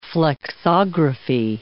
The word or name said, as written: flexography